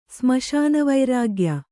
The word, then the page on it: ಸ್ಮಶಾನವೈರಾಗ್ಯ: smaśana vairāgya